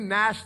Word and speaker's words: gnashed: And they gnashed their teeth at him. You know it's a bad day when people are gnashing their teeth at you. Any word with a silent G is a crazy word.